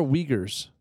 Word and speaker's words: Uyghurs: So what are Uyghurs? – That's what they are.